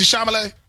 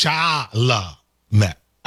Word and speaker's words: Chalamet: Is it Timothy Chalamet? – He said Chalamet.